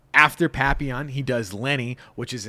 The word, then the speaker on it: Papillon: Anyway, after that, in 73, does Papillon.